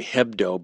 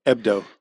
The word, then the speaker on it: Hebdo: In some languages, the H is silent. – Yeah. – So people are saying Charlie Hebdo, but it's really Charlie Hebdo.